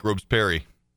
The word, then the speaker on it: Robespierre: Robespierre. Yeah. Oh, it's, but in Maine, you'd pronounce it like Robesperry.